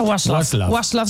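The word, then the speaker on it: Václav: Waslov Smil.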